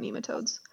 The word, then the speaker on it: nematodes: Good times learning about nematodes.